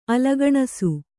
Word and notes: ಅಲಗಣಸು: alagaṇasu

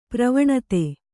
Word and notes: ಪ್ರವಣತೆ: pravaṇate

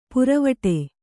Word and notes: ಪುರವಟೆ: puravaṭe

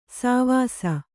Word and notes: ಸೇವಿಸು: kannada

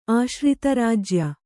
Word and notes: ಆಶ್ರಿತರಾಜ್ಯ: kannada

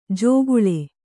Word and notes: ಜೋಗುಳೆ: jōguḷe

